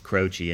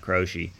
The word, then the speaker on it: Croce: That's Not the Way It Feels by Jim Croce. Yeah. And I learned it was Croce and not Croce.